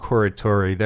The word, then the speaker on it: Corritore: And then we went into Cairo Blues by John Primer and Bob Carter or Orione. And that's the best I'm not going to try and say it the right way because pronunciation of complicated names is Bob Corritore.